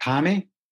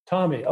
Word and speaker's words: Thame: And I think, help me if I'm right, that the name of the town, a small little town, village really, was called Thame. Is that how you pronounce that? – The town I grew up is called Thame. – Thame, okay. – Thame is the pronunciation, which is very remote village.